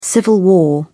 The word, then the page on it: civil war: British English